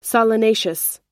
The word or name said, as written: solanaceous